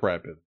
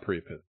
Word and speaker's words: priapism: It's pronounced priapism. I thought pre-pit, but it's not.